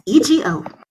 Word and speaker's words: Masego: Masego.